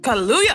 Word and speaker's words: Kaluuya: Let me look up how to say his last name. I can't just be like, call the nigga Kaluuya and just like... Kaluuya pronunciation. Kaluuya. I was close.